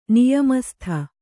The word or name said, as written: ನಿಯಮಸ್ಥ